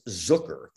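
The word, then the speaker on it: Zuker: For me, my name is spelled Zucker, Z-U-K-E-R. A lot of my family says Zucker. That's how they pronounce it. When I started to get into a situation where like I needed people to know my name and spell it right without a C, I said, I made it long U just because it looks like Zucker.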